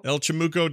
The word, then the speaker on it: El Chamuco: You got the how do you say the Diablo one? El Chamucho? El Chamuco? – El Chamuco Diablo.